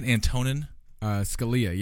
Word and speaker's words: Antonin Scalia: Antonin? – Scalia, yeah. Antonin Scalia.